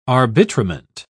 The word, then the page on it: arbitrament: British English